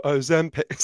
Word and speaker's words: Ozempic: So the correct pronunciation is Ozempic. Sorry, you don't know. That's the extremely annoying jingle in the adverts. It's Ozempic, yeah.